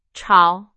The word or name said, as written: 吵